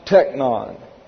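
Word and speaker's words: teknon: This verse begins with a vocative of address from the noun technon. T-E-C-H-N-O-N. Oh my goodness. This is a kappa that makes that a K. T-E-K-N-O-N. Technon. Pronounced same either way.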